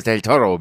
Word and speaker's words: del Toro: Und ich muss auch sagen, wir haben wieder eine Parallele geschaffen zu eurem äh, Platz davor, denn es spielt auch Benicio del Toro mit. – Oh, das war die geilste äh, Aussprache von Benicio El Toro. Del Toro.